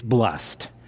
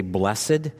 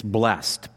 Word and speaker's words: blessed: The first word of Psalm 119 is one syllable. It's blessed. Now, I know when we sing, we sometimes say blessed, but it's one syllable. It's blessed.